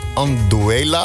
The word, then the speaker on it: Anduela: Er komt ook een spraakbericht binnen van Anduela, als ik het goed uitspreek. – Dat spreek je goed uit.